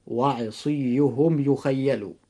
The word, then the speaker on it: وعصيهم يخيل: وأيضا كلمة وعصيهم يخيل، لأنه جاء بعد الميم الساكن في عصيهم حرف الياء، فإذا وقع حرف الياء بعد الميم الساكن بخلاف حروف الهجاء المختلفة فيجب أن ننطق هذه الكلمة هكذا: وعصيهم يخيل.